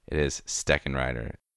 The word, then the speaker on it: Steckenrider: Isn't it Steckenrider? – Is it Steckenrider? Did I not? – I think you missed a syllable in there. – Yes, I did. It is Steckenrider.